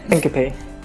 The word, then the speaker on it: syncope: Syncope. Fainting. – Syncope and fainting, or all of the above, because they often come hand in hand, let's face it.